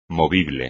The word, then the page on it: movible: espagnol